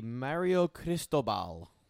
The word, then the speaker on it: Mario Cristobal: The Miami Hurricanes have announced that their new head football coach will be Mario Cristobal.